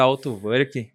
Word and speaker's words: Autowerk: grande amigo aqui, Autoverk. Vamos, vamos bater um papo. É assim a pronúncia correta? Autoverk. Autoverk, Autoverk. É. é.